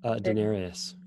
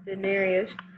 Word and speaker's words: denarius: They brought him the denders. I'm pretty sure it's how you say it. – Denarius. Yep. – Denarius.